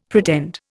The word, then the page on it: pretend: Indian